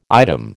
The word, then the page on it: item: American English